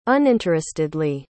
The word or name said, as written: uninterestedly